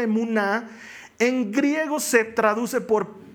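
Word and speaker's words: emuná (אמונה): La fe en hebreo se pronuncia emuná.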